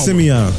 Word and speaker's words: Simeon: I didn't know how to pronounce it. – SM? – And I was like, SM City, SM City. But the track was banging, so. – My name is Simeon, okay? That's my real name.